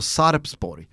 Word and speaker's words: Sarpsborg: Eh, varför sitter de och säger Sarsborg när det är ett P-namnet? Säg P, uttala P så slipper folk rasa.